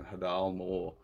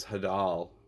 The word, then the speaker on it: Hadal: I've been pronouncing it wrong this uh, for a long time. I think I was pronouncing it Hadal more for a long time. Um, for some reason, I thought it sounded like it was Hadal.